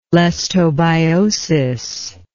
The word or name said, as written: lestobiosis